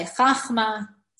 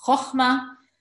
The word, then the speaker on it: chokhmah: I don't know why people say chachma. It's not chachma. It's chokhmah.